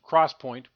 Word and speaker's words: XPoint: Optane is an Intel trademark for the 3D XPoint, pronounced cross-point, memory it co-developed with Micron, which is non-volatile like NAND flash.